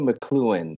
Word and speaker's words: McLuhan: Is that how you say his name? – It's Marshall McLuhan, M-C-L-U-H-A-N. – Okay, yeah.